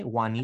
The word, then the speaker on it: Juanita: Or maybe the J is the, oh, it's pronounced as a Y. Juanita.